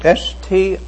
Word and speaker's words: strait: S-T-R-A-I-T. Now there's another word, it's pronounced the same way, but it's spelled differently. S-T-R-A-I-G-H-T. And the G-H turns the whole thing into something else. S-T-R-A-I-G-H-T means the shortest distance from this point to this point. But S-T-R-A-I-T doesn't mean that.